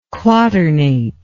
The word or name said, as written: quaternate